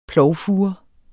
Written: plovfure

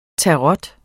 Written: tarot

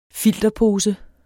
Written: filterpose

